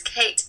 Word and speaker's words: Kate: Okay, it's Kate Thomas, K-A-T-E, followed by Thomas, T-H-O-M-A-S.